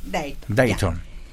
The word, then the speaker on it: Dayton: Dayton, ¿cómo se pronuncia ahí? – Dayton.